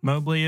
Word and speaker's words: Mobley: Mobley is spelled M-O-B-L-E-Y.